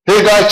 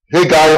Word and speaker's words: Haggai: Haggai chapter 1, or Haggai or Haggai, if how you want to pronounce it, depending on where you come from.